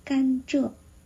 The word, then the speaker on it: 甘蔗: 折就是甘蔗，在粤语里面我们不会说甘蔗，如果甘蔗在粤语的文字上我们发音甘蔗，记住了，甘是闭唇音，甘蔗，但是口语里面我们不会说甘蔗，我们只需要说蔗就可以了。